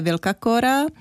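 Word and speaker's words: Vilcacora: Tak, Wilsa Cora. – Správně se to vyslovuje Wilka Cora.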